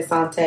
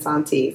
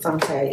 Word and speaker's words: Sante: And I feel like the last time I told the story, I kept mispronouncing her name. I'll say Sante and then Santi. So I'm gonna stick with Sante, even though there's no accent on E.